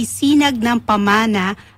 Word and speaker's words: Sinag ng Pamana: So, ito po ang tawag ay Sinag ng Pamana, a gala of the Philippine heritage where culture meets couture. Did I pronounce that right? – Yes.